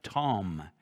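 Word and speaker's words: tom (תם): It's the Greek or Hebrew word tom, T-A-M, tom, pronounced like T-O-M.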